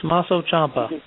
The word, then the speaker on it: Tommaso Ciampa: Tommaso Ciampa, I know I ain't pronouncing that right, versus Tommaso Ciampa.